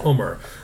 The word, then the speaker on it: Umar: No, because I think ever since I started my speaking career, I was going with Bobby Umar for the longest time. And only in the last 3-4 years have I been saying, no, it's pronounced Umar.